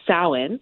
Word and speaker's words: Samhain: So um, a lot of traditions came out of um, this festival, which they call Samhain, and it's it's spelled not like it sounds in in our with our accent. It's spelled S A M H A I N, um, and that was their traditional festival.